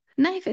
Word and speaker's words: knife: But we went over how to pronounce a knife because it has that silent K at the beginning of the word.